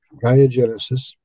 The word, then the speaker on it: GaeaGenesis: GaeaGenesis. Am I pronouncing it that right, dude? – That's right, GaeaGenesis.